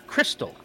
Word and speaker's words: KRXSTXL: The name is pronounced Crystal.